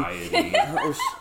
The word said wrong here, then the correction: deity: Deity? – Yeah, deity.